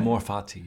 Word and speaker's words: Amor Fati: And um, Amor Fati, is that am I saying that right? Amor Fati.